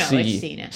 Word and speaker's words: Cena: I hope I'm pronouncing that right. That was probably pronounced Cena. Anyway, Cena. – No, it's Cena.